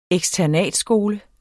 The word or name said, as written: eksternatskole